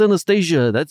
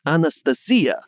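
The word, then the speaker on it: Anastasia (Анастасия): I saw a lot of angry Russian YouTubers saying it's not pronounced Anastasia. It's Anastasia.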